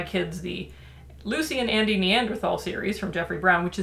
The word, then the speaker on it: Neanderthal: First of all, I just want to say, I'm pronouncing it Neanderthal. There is a contingent of people who believe it's pronounced Neanderthal. Both are equally valid. I just prefer the thal because there's a freaking TH in it.